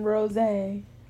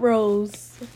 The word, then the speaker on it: rosé: We're drinking rosé. Not rose. Rosé. Accent on the E. Had to correct my baby.